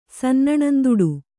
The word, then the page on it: ಸನ್ನಣಂದುಡು: kannada